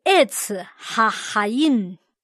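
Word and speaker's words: Etz Hahayim (עץ החיים): 生命树读音是 Etz Hahayim。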